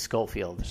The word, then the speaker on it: Schofield: It's not Schofield, it is Schofield.